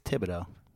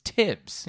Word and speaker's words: Thibodeau: Yo, if you're an MVP, how do you say it? Tibbs or Thibs? – I think it's Thibodeau. – Thibodeau. I've been saying it wrong. Correct me. – It is Thibodeau, right? – I think you're right. I've been saying it wrong. I say Thibs. It's Tibbs.